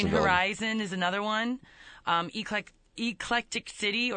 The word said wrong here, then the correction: Eclecticity: or Eclectic... I never say it right. That's why I didn't read the spot when we did it. – Eclecticity.